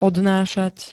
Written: odnášať

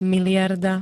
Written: miliarda

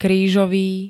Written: krížový

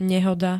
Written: nehoda

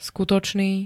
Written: skutočný

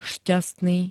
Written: šťastný

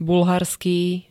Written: bulharský

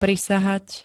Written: prisahať

